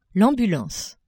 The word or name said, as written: ambulance